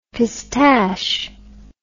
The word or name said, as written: pistache